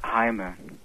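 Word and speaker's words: Jaime: Ach, ich muss dich korrigieren, das heißt Heime. – Wie heißt das? – Heime? Ja, richtig, das wird praktisch wie ein H ausgesprochen.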